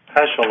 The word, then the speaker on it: Heschel: Heschel? – Heschel, Avrom Shia Heschel.